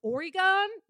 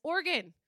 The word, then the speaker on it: Oregon: Oh, you're from Oregon? No, it's Oregon.